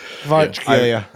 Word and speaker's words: Kvaratskhelia: Kvaratskhelia.